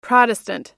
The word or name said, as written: protestant